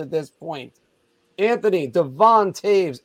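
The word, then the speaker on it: Toews: And after last night, Devon Taves, there you go, Anthony, actually said his name correctly, called out his teammates.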